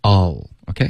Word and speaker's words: I'll: I'll 这个发音啊、也可以，可是我们最好用这个弱读，all，OK？、 Okay? 把它发成 all、嗯。